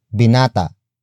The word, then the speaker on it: binata: After that, we have binata. Binata. Binata. Binata is spelled as B-I-N-A-T-A.